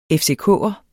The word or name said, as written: FCK'er